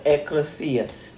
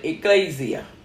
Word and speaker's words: Ecclesia: Because that's the way it's pronounced, the Ecclesia. Some people call it Ecclesia. Other people, like the Spanish, call it Iglesia. Amen. Hallelujah. But the word is Ecclesia.